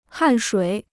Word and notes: 汗水: hàn shuǐ

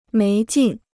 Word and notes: 没劲: méi jìn

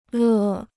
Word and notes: 乐: lè

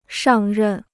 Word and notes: 上任: shàng rèn

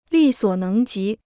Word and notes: 力所能及: lì suǒ néng jí

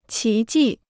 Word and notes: 奇迹: qí jì

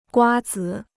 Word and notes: 瓜子: guā zǐ

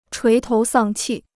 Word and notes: 垂头丧气: chuí tóu sàng qì

